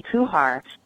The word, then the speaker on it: Kuchar: I think I've been pronouncing it Katie Kuchar.